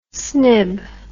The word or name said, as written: snib